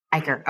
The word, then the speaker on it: Eicher: Eicher.